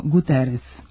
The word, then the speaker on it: Guterres: Antonio Guterres. Zato što se, prema pravilima transkripcije sa portugalskog, koje su obrađene u pravopisu, portugalsko krajnje S prenosi kao S, a ne kao Š.